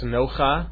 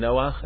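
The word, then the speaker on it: noach (נח): Nobody says nocha or rucha or kocha. Everybody says ruach and noach and koach, because that is called a patach genuva.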